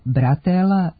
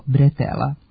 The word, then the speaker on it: bretela: Međutim, naramenica kao deo grudnjaka, potkošulje, kombinezona, letnje majice ili haljine, remen ili kajš za nošenje tereta nije bratela, nego bretela. Potiče od francuske reči bretel, koja u izgovoru ima nemo e, a ono se u naš jezik prenosi kao e.